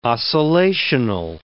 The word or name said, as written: oscillational